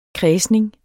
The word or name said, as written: kredsning